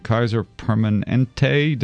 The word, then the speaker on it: Kaiser Permanente: We're here this morning to speak with the good folks from Kaiser Permanente.